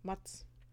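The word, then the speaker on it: Mads: Ich kann seinen Namen Metz. nicht aussprechen. Mats. Mats. – Nein, Mats, Mats so.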